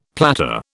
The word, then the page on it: platter: British English